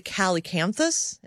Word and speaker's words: calycanthus: But the calycanthus, am I saying that right? – Yeah.